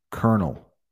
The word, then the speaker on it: Colonel: And Colonel, I mean, don't even look at that word if you want to pronounce it. Colonel. Colonel. Colonel? Yeah. I did a short. I know it's on Instagram, maybe on Facebook too, about kernels of corn.